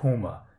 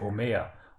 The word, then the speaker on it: Homer: Bitte sagen Sie nicht Homer, ich heiße Homer und werde auf der letzten Silbe betont.